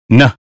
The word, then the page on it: name: American English